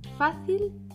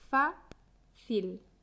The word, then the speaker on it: fácil: Tiene dos sílabas. FA-CIL, FA-CIL. La sílaba fuerte es fa. Fa. Fácil. Fácil.